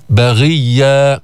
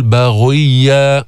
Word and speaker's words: باغية: طيب من أمثلة الغين من أمثلة الغين المكسورة من غل، هكذا تلفظ باغية باغية، ولا تقل باغية باغية.